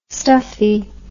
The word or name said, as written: stuffie